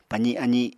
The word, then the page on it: pazi azi: Apatani